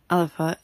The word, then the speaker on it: Alba: Now, the word alapa is written alba, but it's not pronounced that way.